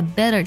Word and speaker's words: better: 那么这句话当中呢，要注意一下 better 这个单词，字母 tt 呢，我们这里浊化成的的音，读作 better。